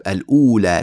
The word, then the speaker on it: الأولى: وكلمة الأولى يجوز في كتابتها واو والواو لا تنطق طبعا تكتب الأولى لكن لا تنطق هذه الواو تنطق الأولى